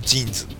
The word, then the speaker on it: JINS: 同じスペルなんでね、 JINS と書いて、会社名は JIN で、ブランド名はジーンズっていうなんかね、